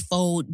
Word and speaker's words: faux: How do you say F-A-U-X? Fox. – It's faux. – Is it faux?